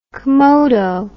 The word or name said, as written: comodo